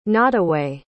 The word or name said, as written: nottaway